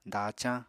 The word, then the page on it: dacañ: apatani